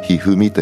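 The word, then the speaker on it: 一二三: 普通の小さな林の小林で、数字、漢字の 1 ね、それから 3、あの将棋のひふみんっていうね、人気のおじいちゃんはいらっしゃるけど、あの人は 123 って書くでしょ。であれでひふみと読むんだけど、この小林さんは 1 と 3 だけで、一蔵と読むんだね。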